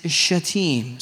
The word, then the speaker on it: Shittim: Y'all mispronounced that. And it's just an excuse for you to cuss, okay? That is not how it is pronounced. It is Shittim. So quit cussing.